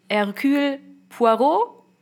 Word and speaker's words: Hercule Poirot: Hercule Poirot glaube ich.